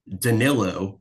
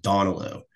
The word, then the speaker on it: Danilo: Everybody says Donilo. Like, where are you looking at that name and thinking that Donilo is how you pronounce this man's name?